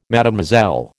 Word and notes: Mlle: American English

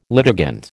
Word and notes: litigant: American English

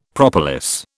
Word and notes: propolis: British English

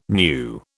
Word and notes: knew: British English

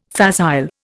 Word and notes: facile: Indian

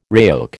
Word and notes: RAOC: American English

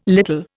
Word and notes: little: Indian

